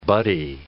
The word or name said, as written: buddy